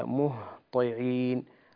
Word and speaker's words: مهطعين: كلمة مهطعين ينبغي العناية فيها بحرف الهاء وحرف الطاء، تنطق هكذا: مهطعين مهطعين. كثير من الناس لا يأتي بالهاء على وجهها الصحيح، أي ينقص من بعض صفاتها، وهذا لا يصح.